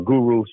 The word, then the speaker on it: Guro: Guru spelled G-U-R-O.